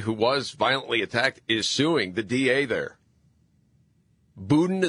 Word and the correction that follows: Boudin: Boudin. – Yeah. – No, not Budin. Yeah, Boudin.